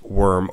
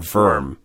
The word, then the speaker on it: wyrm: And I'm saying wyrm, even though it's actually pronounced worm or verm, right.